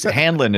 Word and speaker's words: Hanlon: A lot of people say his name wrong, and I don't know why. It's something. – Hanlon? – It's burned into my head. It's Hanlon.